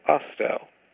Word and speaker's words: Ostow: much more American, two-syllable Ostow. O-S-T-O-W.